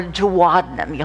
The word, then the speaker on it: duodenum: That is the duodenum. Or duodenum, you'll hear that pronounced both ways.